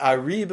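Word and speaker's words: Ari (אריה): But Arib Mistarim, it's a Korean exiv, by the way. It's written Aleph Resh Yud Hey, Aryeh, and it's pronounced Ari.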